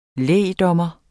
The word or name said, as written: lægdommer